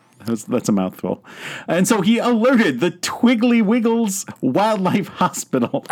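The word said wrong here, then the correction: Tiggywinkles: I think you said that wrong. – Did I? – The Twiggly Wiggles? – Sorry, the Tiggy Winkles.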